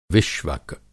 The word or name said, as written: विष्वक